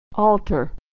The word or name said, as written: altar